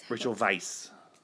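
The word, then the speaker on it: Rachel Weisz: Then we would end up like Rachel Wise? – Rachel Weiss.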